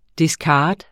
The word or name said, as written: discard